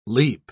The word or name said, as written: leap